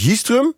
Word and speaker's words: Jistrum: Ervaringsdeskundige Rinse Pol, 65 jaar, uit Jistrum. Zeg ik dat goed zo, Rinse? – Ja, klopt.